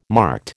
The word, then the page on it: marked: American English